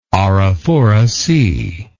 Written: Arafura Sea

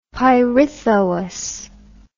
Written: Pirithous

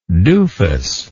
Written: dufus

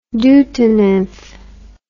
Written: deutonymph

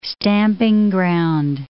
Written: stamping ground

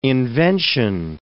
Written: invention

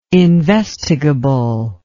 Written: investigable

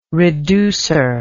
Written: reducer

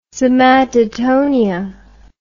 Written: somatotonia

